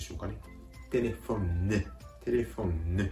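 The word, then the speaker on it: téléphone: テレフォンね。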